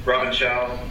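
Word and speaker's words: Robin Shou: Robin Shu, I believe it's pronounced. – Robin Chow, I believe. – Yeah, Chow.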